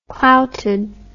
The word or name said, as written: clouted